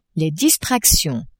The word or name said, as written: distractions